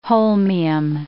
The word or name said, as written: holmium